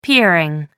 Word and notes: peering: American English

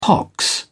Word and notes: pox: British English

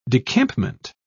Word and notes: decampment: British English

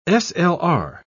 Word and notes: slr: British English

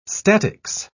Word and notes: statics: British English

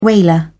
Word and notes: whaler: British English

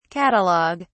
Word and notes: catalog(ue): British English

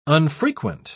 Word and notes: unfrequent: British English

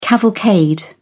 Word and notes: cavalcade: British English

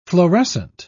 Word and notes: florescent: British English

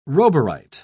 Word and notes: roburite: British English

